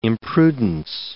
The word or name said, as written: imprudence